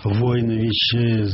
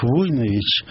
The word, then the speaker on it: Войнович: Потом мне тетя моя рассказывала, что вот она рассказывала, что вот был такой русский адмирал Марко Иванович Войнович, значит, да, она мне говорила, ну и отец мой говорил, отец даже боролся за ударение на первом слоге.